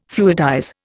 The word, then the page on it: fluidize: Indian